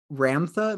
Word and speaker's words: Ramtha: 1990, you enrolled in the Ramtha, am I saying that correctly? – Correct, yeah.